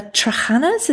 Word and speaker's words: trahanas: Now, when you're talking about scraping the mud with your little hands, you also used your hands to gather the trahanas. Is that how you pronounce it? – Yes.